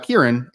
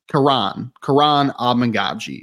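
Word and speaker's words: Kiran Amegadjie: So his name, I thought it was Kieran. It's Karan Amangaji is how you say his name.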